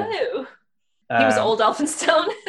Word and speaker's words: Elphinstone: Yeah, that's spelled E-L-P-H-I-N stone.